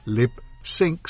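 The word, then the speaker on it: Lipps Inc: Y en 1980, Lip Sync, pronunciado Lip Sync, sincronización de labios, estrena Funky Town.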